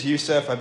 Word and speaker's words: Yousef: No, my name is Yousef. Thank you for the beer. I've been pronouncing it Yosef because, like, people start thinking I'm Jewish, and I like that.